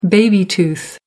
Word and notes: baby tooth: American English

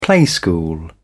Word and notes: playschool: British English

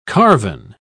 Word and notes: carven: British English

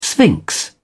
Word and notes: sphinx: British English

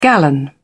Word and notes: gallon: British English

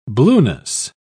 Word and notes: blueness: British English

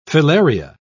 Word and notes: filaria: British English

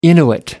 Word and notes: inuit: American English

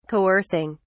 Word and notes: coercing: British English

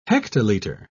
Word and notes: hectoliter: British English

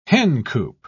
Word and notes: hencoop: British English

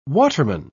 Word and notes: waterman: British English

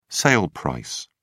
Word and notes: sale price: British English

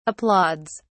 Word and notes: applauds: British English

